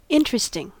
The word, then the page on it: interesting: American English